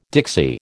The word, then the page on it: Dixie: American English